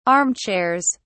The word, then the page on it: armchairs: British English